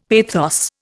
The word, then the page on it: pathos: Indian